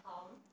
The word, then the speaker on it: palm: with my palm. – Yes, mm-hmm. Palm. L is silent.